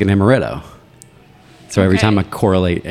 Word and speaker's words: amaretto: Which actually, I think amaretto. I'm trying to remember what all's in it. I know it's like an. It might be the amaretto. Actually, am I saying that right? – Yeah, you are.